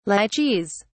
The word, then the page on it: lychees: British English